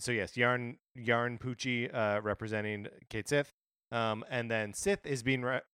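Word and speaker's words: Cait Sith: I mean, just for the record, it is spelled K-I-T. – C-A-I-T. – C-A-I-T space S-I-T-H. Kate Sith, but it's like Scottish or something. Ket she anyway.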